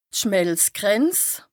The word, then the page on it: d' Schmelzgrenz: Bas-Rhin; Strasbourg